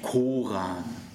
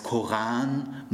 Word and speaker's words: Koran: Ihr sagt ja auch nicht Koran. Ihr sagt ja auch nicht Moschee. Das heißt Koran, Moschee, die arabischen, semitischen Worte werden alle auf der zweiten Silbe betont.